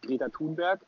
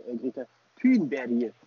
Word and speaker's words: Greta Thunberg: Ich habe jetzt übrigens gehört, die heißt ja nicht Greta Thunberg, sondern Greta Thunberg. – Richtig so. Ausgesprochen.